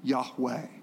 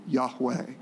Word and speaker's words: Yahweh: The literal Hebrew for this uppercase Lord is a word that is pronounced Yahweh, Yahweh.